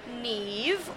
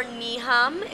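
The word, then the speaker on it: Niamh: Yeah, so it's N-I-A-M-H and pronounced N-E-V-E. Super confusing for people.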